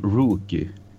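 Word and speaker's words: Roky: Och då tänkte jag bara så att alltså jag har alltid kallat eh, den här killen från 13th Floor Elevators för Rocky Eriksson. Bara så du klarar över det. får säga, det, var, det var dubbelfel där. För man, man uttalar Rocky som Rookie Eriksson.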